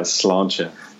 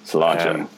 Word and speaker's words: sláinte: Yeah, so yeah, sláinte. – Sláinte. – Which is spelled as if it's slainty, but it's pronounced sláinte.